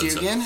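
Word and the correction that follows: Dungen: Or Dugan? Oh, Dunyan? – Dunyan? – The Swedish band? – That's how you pronounce it?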